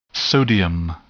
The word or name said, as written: sodium